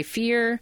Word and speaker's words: Fier: And if anybody is curious about what events may happen, you can find me all over the various social medias under TJ Fear. It's usually I Am Fearless. The last name is spelled F-I-E-R, just in case you're looking for it.